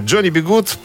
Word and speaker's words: Johnny B. Goode: Полное имя Джонни Бигуд произносится на английском так же, как и фраза э, «Джонни, запятая, Бигуд», типа «Будь, Джонни, будь хорошим».